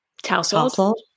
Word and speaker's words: tousled: I'm looking at the directions and it says dry finishing spray builds instant fullness and matte texture for perfectly, how do you say this word? T-O-U-S-L-A-D. – Tousled.